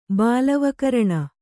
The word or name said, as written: ಬಾಲವಕರಣ